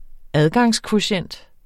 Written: adgangskvotient

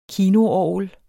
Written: kinoorgel

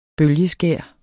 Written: bølgeskær

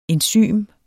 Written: ENZYM

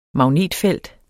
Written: magnetfelt

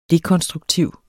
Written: dekonstruktiv

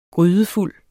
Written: grydefuld

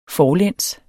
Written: forlæns

